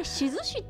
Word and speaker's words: 質志: そしてあれしずしって読むんです